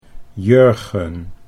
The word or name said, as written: Jurgen